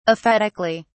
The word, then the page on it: aphetically: British English